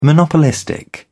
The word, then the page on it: monopolistic: British English